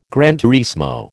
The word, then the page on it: gran turismo: American English